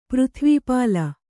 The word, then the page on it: ಪೃಥಿವೀಪಾಲ: přrhivī pāla